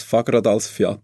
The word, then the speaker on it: Fagradalsfjall: Ja, ich habe es falsch ausgesprochen. – Ja, knapp. Also ich bin auch nicht perfekt isländisch. Eigentlich sehr wenig, aber heißt so viel, ich weiß, Fagradalsfjall.